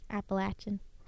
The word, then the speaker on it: Appalachian: Did I say that right? Appalachian. – Thank you. Artist. – But it's okay. Falls in line with what we said growing up. I said Appalachian.